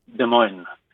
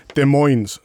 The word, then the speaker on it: Des Moines: ja, eh, Des Moines, kan du uttale det på fransk? – Des Moines. – Ja, men det uttales ikke sånn på fransk. Det, uttales, det, det er oppkalt etter ei elv som heter Rivière de Moine. Så det er amerikanerne som ikke klarer å uttale OI på fransk som OA, men eh, må si Moine.